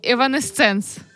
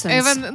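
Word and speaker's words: Evanescence: Нам пані Настя Малкіна написала, а я досі не знаю, як воно правильно читається, хоча в мене теж подібний був. В неї висіли плакати таких гуртів, як Нірвана і Еванесценс. – Еванесенс.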